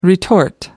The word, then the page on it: retort: American English